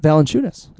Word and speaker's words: Valanciunas: Is that how you pronounce it, Valanciunas? – Valanciunas. Oh. Jonas Valanciunas.